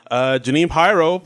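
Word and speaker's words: Jeanine Pirro: So one of the first people on Weekend Update was Janine Pyro, played by Cecily Strong.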